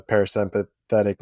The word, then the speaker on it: parasympathetic: And so wrap this back to Qigong a little bit, do you ever find yourself in that fight or flight, the parasympathetic nervous system activation? I think I pulled that off. I think that was the right way to pronounce it. – That was correct.